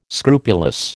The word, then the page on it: scrupulous: American English